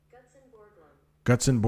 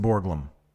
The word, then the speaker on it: Gutzon Borglum: The correct pronunciation of Guzog Borglum. Gutzon Borglum. Gutzon Borglum.